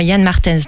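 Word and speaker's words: Jan Martens: Effectivement, Yann Martens, donc tu l'as bien prononcé,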